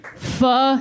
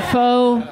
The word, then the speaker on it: pho: It's pronounced pho hip hop. He said pho, and it's.